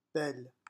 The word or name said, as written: belle